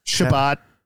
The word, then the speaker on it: Shabbat: They pronounce it like a different way? – Shabbos? – So, Shabbat. – Shabbat. Yeah, that sounds Shabbos. About right. Shabbos. – There's different pronunciations, but the Jews and Seventh-day Adventist Christians have the same Sabbath day.